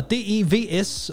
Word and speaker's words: DEVS: Og Marie, vi skal anmelde en ny serie, der hedder DEVS, og som jeg tror udtales Devs. Var det sådan, det var? – Ja, det er rigtigt nok.